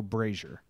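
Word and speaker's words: Brasier: It's pronounced like Frazier, so Brazier.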